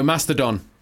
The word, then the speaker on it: Mastodon: Mastodon. – Can I say it like that? – No, Mastodon.